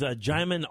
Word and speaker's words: Djimon: His name is Djimon Hounsou.